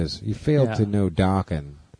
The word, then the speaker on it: Dokken: Dokken doesn't rhyme with metal. It rhymes with rockin'.